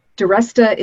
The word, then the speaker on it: Deresta: Or as my friend Bruce Weinstein says, Deresta is besta, or some kind of thing, is the besta. So Deresta rhymes with restaurant.